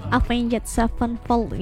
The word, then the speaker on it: Avenged Sevenfold: Avenged Sevenfold ya.